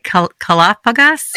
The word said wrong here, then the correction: Kalofagas: Kalofagos. – Kalofagos. – The accent on certain syllables is different. So it's kalo, which is good. Fagas is eater. And so it's a compound word in Greek, kalofagos.